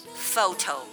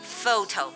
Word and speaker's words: photo: ph 既不发 p，也不发 h，而是发 f 的 f。 For example, photo, photo 照片。